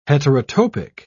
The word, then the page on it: heterotopic: British English